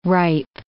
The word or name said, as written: ripe